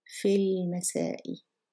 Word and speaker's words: في المساء: وأدرس في المساء Notice that I pronounced, I did pronounce the, I did pronounce the lam, fil masai, because mim is a moon letter.